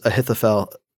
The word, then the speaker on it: Ahithophel: And so we get this whole Hushai versus Ahithophel. Yeah, that's how you say it.